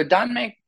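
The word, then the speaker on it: danmei (耽美): So danmei is the Chinese pronunciation, or my attempt at the Chinese pronunciation of the word tanbi, again, aesthete.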